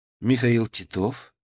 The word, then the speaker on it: Титов: «Михаил Титов?» — спросил встречающий, поставив ударение как положено, на второй слог.